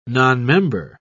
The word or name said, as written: nonmember